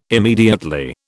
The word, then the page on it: immediately: British English